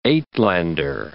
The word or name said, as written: Uitlander